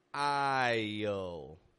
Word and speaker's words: isle: And to pronounce it as clearly as possible, drop that S out of there and use that Y glide, the I part, to connect your sounds together. I-le, connecting that I to the dark L. And then I've got one more for you, aisle. That's right, aisle. It's spelled A-I-S-L-E.